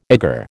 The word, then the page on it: egger: American English